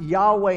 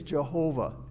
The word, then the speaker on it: Yahweh: It's possibly pronounced Yahweh in Hebrew, but traditionally in English, it's Jehovah.